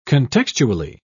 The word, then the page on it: contextually: British English